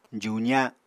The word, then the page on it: juza: apatani